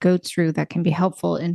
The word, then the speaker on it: Goat's rue: Goat's rue. Goats, like a goat with an apostrophe, S-R-U-E.